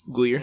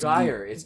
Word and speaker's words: Geyer: Gleer. – Amber... – It's Gleer. – It's Geyer. Geyer, right? – Thanks for the pronunciation. – Yeah, and it's spelled like Geyer, though. Like, it's spelled like Guy, E-R.